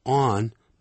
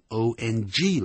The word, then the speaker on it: on: 凡人用的 spend 这个字啊，永远脑筋要想着它的主语一定是一个人，人加 spend 加时间或金钱，再放动名词，或者也可以加 on 再放呢名词。别忘了那个介词啊 on，不要念成 on 啊，要念 on 的是 ong 了，它是 on 而不是 ong，是念 on，像安全的安，嘴巴再圆一点就 on。